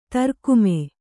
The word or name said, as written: ದ್ರಾಘಿಮ